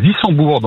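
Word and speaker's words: Wissembourg: À Dissembourg, dans le nord du Bas-Rhin.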